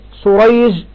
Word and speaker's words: سريج: ها، النطق في سريج وشريح، تمام، سريج وشريح مختلف، النطق مختلف. النعمان والنعمان واحد، يبقى الاختلاف في أسماء الأبناء والاتفاق في أسماء الآباء. قال: وكلاهما مصغر، شريح سريج، فالأول بالمهملة يعني بالسين والجيم، سريج ابن النعمان ابن مروان اللؤلؤي البغدادي روى عنه البخاري وروى له أصحاب السنن، والثاني بالمعجمة الشين شريح والحاء المهملة شريح.